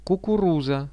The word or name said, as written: kukuruza